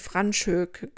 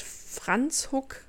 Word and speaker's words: Franschhoek: Hm, als ich das erste Mal den Namen des Orts gelesen habe, habe ich Franschhoek gesagt. Also so, wie es quasi jeder Deutsche vermutlich für sich liest. Aber es wird Franzhuck ausgesprochen.